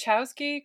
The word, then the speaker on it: Wachowski: I think it's Wachowski because Americans tend not to go with the like original pronunciation.